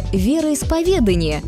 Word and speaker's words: вероисповедание: Например, вы знаете, где поставить ударение в слове «вероисповедание»? Да, именно на «е», а не на «а». Веру исповедуют, и никак иначе.